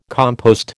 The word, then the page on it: compost: American English